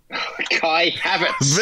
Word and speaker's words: Kai Havertz: His first name rhymes with lie. His last name rhymes with Smaverts. – Kai Havertz.